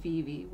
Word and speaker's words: VeVe: And if you're interested in something like that, you can actually download the app called Veevee, which is spelled V-E-V-E.